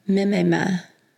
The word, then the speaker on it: mimema: Which modern term comes from the Greek word mimema? And that's spelled M-I-M-E-M-A. Mimema. Or mimema.